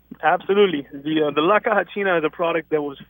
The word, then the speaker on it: La Caja China: And Jop, you and I over the years have talked to people who use China boxes, only we've been saying it wrong.